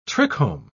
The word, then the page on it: trichome: British English